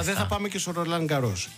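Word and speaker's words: Roland-Garros: Το πήγε με προφορά, Ρολάν Γκαρός.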